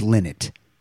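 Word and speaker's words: Lynott: People in Dublin told me it's Linot.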